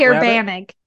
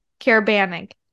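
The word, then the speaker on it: Caerbannog: How do you say it? The what? – Carabanig. Carabanig. – Carabanig rabbit? – Yeah. Carabanig rabbit.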